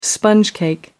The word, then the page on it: sponge cake: British English